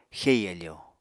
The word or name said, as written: xei áfò